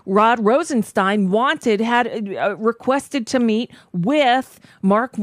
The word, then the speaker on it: Rosenstein: How difficult is it to get the pronunciation of the man's name right? Rosenstein, not Steen, but yeah, it's what? – Dr. Frankenstein.